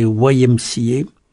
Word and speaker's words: YMCA: Alors l'IMCA, on peut le prononcer de 2 façons. IMCA, c'est la façon française, et la façon anglo-saxonne, c'est YMCA.